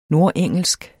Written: nordengelsk

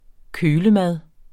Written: kølemad

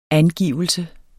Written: angivelse

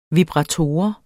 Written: vibrator

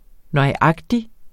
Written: nøjagtig

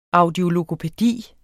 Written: audiologopædi

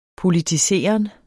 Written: politiseren

